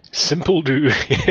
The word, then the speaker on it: SimpleDo: SimpleDo, yes. I almost can't stop laughing when I say the name out loud. It doesn't look so bad on the screen, but when you say SimpleDo out loud, you feel like a bit of an idiot.